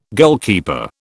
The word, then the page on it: goalkeeper: British English